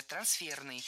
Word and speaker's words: трансферный: Трансферное, неверно. Правильно, трансферное, трансферный. И здесь опять же шпаргалка, исходное существительное «трансфер». Оно французского происхождения, имеет ударение на втором, то есть последнем слоге. Трансфер. И такое же ударение сохраняется в прилагательном. Трансферный. Ну а теперь мы слушаем гостя программы «Хорошо темперированный эфир».